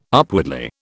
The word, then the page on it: upwardly: British English